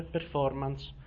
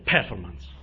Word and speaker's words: performance: Ripeto, non so se ve l'ho detto, si dice performance, non si dice performance, come tutti dicono. Come management si dice management e non si dice management. Piccole parole che vanno in qualche modo sapute dire, perché si fa un'altra figura.